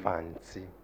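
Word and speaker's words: Fante: How do you pronounce it? – Fancy. – Like that, yes.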